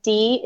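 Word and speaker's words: Dee: So our 5 categories are C is for cookie, O rhymes with Mo, the flying V, there's no I in freedom, and D is my last name, and that's D-E-E.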